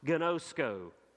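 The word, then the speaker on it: gnosko (γινώσκω): In the original language, the Greek of which the New Testament is written, the word know there, it is a word that you and I would pronounce gnosko.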